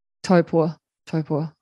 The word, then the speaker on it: Taupo: Taupo. I'm trying to get my, say it right. Like, went on a girls trip in the weekend. Yeah. And friend explained it, they say toe and then poor.